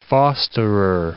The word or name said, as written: fosterer